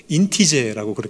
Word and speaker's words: INTJ: 요즘 젊은이들은 이것을 발음 나는 대로 인티제라고 그렇게 부릅니다.